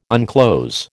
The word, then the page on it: unclose: American English